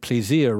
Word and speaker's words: plaisir: And Debussy said famously, there is no rule in music, just your ear, just plaisir. And I don't know that he realized that plaisir rhymes with the English word ear when he said that.